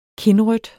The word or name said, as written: kindrødt